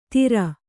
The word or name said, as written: ಧೀರ